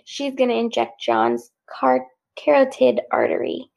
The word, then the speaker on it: carotid: It's one of those words that looks weird when you read it. – Yeah. Carotid. There you go. – Carotid. – That's how you say it.